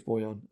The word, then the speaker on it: Bojan: Like, we saw how quickly, like, the Hawks, like, Bojan Bogdanovic, I think it's Bojan. I think that's how you pronounce it.